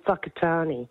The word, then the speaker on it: Whakatāne: It's I lived in New Zealand and it was a place called Whakatāne.